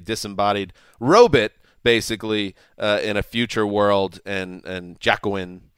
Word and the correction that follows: Joaquin: Is that how you pronounce it? – Joaquin Phoenix.